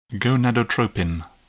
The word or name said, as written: gonadotropin